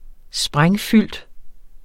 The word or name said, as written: sprængfyldt